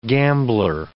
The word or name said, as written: gambler